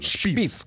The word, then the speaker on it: Шпиц: На свет появился Марк Спиц. Что-нибудь вам говорит? – Спиц, скорее всего. – Ну, Спиц, Шпиц. Значит, Википедия по-разному трактует произношение. Надо по-немецки говорить просто правильно. – Шпиц, конечно. Шпиц, хорошо.